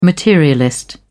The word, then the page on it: materialist: British English